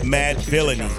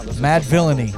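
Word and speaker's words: Madvillainy: And how do you pronounce the name of the album? Because I know we butchered it at the beginning of the show. – Mad Villainy. – Mad Villainy.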